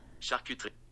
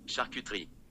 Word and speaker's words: charcuterie: Okay, so let's talk about how to pronounce it. This was interesting. I pulled up a couple different places online how to pronounce it. I'm going to play this. Charcuterie. Charcuterie. Charcuterie. Charcuterie. And then this one. Charcuterie. Charcuterie. Charcuterie. So that's how we say it in the Midwest.